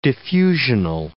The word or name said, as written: diffusional